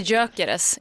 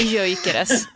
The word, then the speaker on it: Gyökeres: Och då säger jag Viktor Gyökeres. – Och då säger du fel. Han heter Gyökeres, inte Gyökeres.